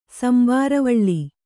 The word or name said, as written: ಸಂಬಾರವಳ್ಳಿ